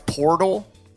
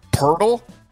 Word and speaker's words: Poeltl: If you ever are watching television, like a Sports Center or anything, and you see a host pronounce Portal Purtle, do you know what they did? They just read it incorrectly.